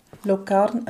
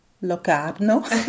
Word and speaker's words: Locarno: Und zwar bin ich im Tessin, ich glaube, es war in der Ferien gewesen, und äh, in Locarno, oh, ich sage es immer falsch, gell? der Tessiner sagt mir ja, ich muss sagen Locarno.